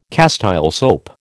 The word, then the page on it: Castile soap: American English